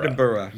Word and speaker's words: Edinburgh: And it's supposed to be pronounced Burrah, the way that you pronounce Edinburgh. – Edinburgh.